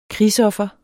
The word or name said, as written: krigsoffer